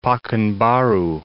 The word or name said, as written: Pakanbaru